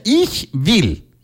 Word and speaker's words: Ich will: Will como ve, como que si ve chiquita. Will. – Ajá. – Como, bill. Como vaca, bill. Como vaca. Will. – Ich will. – ¡Ahí estás! ¡Qué bárbaro! – Yeah. Vale, entonces así se pronuncia.